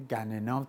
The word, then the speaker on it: گنا: گنا اسم مازندرانیه، گننا تلفظ میشه و تلفظهای دیگه کتابیش نه، گنناست.